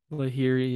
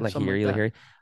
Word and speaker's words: Lahiri: and Lahiri. That one might have been. – Lahiri, yeah. – Lahiri.